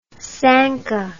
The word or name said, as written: Sanka